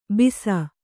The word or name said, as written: ಭಾಸ